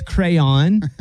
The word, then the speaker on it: crayon: A crown. – I'll accept it. You said crown, but it's crayon.